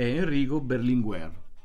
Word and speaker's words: Enrico Berlinguer: Io la prima persona a cui voglio fare riferimento, che è nata proprio oggi, 25 maggio nel 1922, è Enrico Berlinguer, no? Non so se l'ho detto bene. – Benissimo. – Okay.